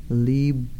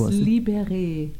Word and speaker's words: Libéré: And I'm like, started like Liba. – It's Libéré. It's because of the accents.